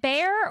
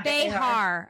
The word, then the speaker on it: Behar: Is it Bear or Behar? – Yeah, Behar.